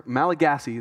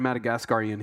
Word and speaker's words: Malagasy: And you know, God brought a family of Madagascar, Malagasy is the right way to say that. I tried Madagascarian, he corrected me, that's not right.